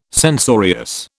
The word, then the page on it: censorious: British English